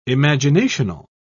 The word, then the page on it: imaginational: British English